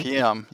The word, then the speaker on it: Municipium: And then we're going to jump to 2008 with Municipium. Did I say that right? – Municipium, yes. – Yes.